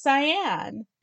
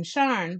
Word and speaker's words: Sian: My choice for an astronaut is Cyan, S-I-A-N, in honor of Cyan Haley Proctor. The pronunciation is often Sharn, but not for Dr. Proctor.